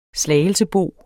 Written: slagelsebo

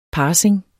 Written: parsing

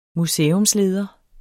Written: museumsleder

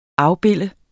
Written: afbilde

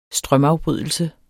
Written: strømafbrydelse

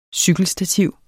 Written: cykelstativ

